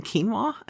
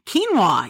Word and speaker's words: quinoa: do you mean quinoa? And I was like, oh, quinoa. Yes, I've been saying that wrong my entire life, right?